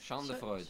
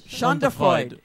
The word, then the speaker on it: Schadenfreude: Schadenfreude. How do you pronounce that? – Schadenfreude. – Schadenfreude. Schadenfreude. You have to think of Sigmund Freud, mm-hmm.